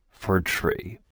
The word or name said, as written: fir tree